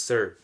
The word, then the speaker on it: Hsern: So my first name is spelled H-S-E-R-N. It's pronounced Sir. You know, very simple, because, you know, H is silent.